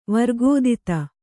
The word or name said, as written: ವರ್ಗೋದಿತ